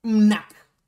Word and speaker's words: Mnak: Lo voy a pronunciar como yo lo imagino. Nac. M-N-A-K T, MNAC. – Entonces. Ah, pero en España se pronuncia las letras tal cual. NAC.